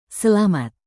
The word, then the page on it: selamat: indonesian